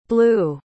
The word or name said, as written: bleu